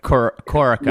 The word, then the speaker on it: Corica: Is it Corica? Is that how you pronounce it? Somebody got on me about how I pronounced it during the week, and I've got it autocorrects on the iPhone to Corsica. Most people end up calling it Corsica. There's a lot of different pronunciations. – I think I called it Corica the right way. Corica.